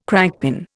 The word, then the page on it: crankpin: Indian